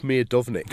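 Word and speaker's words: Miodownik: Mark Miodovnik.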